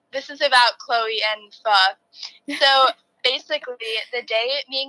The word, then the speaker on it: pho: is pho. So it's spelled pho, pho, and I do pronounce it right, just so you know.